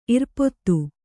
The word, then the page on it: ಇರ್ಪದು: kannada